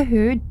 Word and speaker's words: Hou: Yeah, for me, it's Jennifer Hu, pianist, and who is spelled H-O-U, and you can find me on Instagram, YouTube, my website, Facebook.